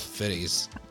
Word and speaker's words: Fiennes: So, or if you say it, Ray Fiennes, I don't know. Like, it's a weird fucking pronunciation. It looks like Ralph Fiennes. – Oh, okay. – But it's Fiennes is like, but he's really good.